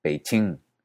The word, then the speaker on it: Beijing: Sin embargo, en el norte del país, el sonido de K y de TS antes de una I se convirtió en Che, y así Pekín se pronunciaba Beijing, Nanking se pronunciaba Nanjing, y Tianjin se pronunciaba Tianjin.